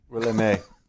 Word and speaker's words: Willamette: It is Willamette, damn it. – I actually pronounce it the way the French pronounce it when they settled the region. Willamette. – Willamette. – It rhymes with damn it, and that's how you can always remember.